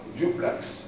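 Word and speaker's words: duplex: ممكن تقول لك انا ساكن في شقة دوبلكس، المصريين هي لغاية دوبلكس، دوبلكس دوبلكس دوبلكس مش مهم، لكن هي النطق صح دوبلكس مستويين.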